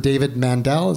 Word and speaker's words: David Mandel: for three seasons with David Mandel.